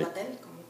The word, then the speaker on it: Bataille: Bataille, como se pronuncia.